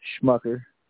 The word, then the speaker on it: Schmucker: You can find me on Facebook under my name, Samuel Schmucker. Last name is spelled S-C-H-M-U-C-K-E-R.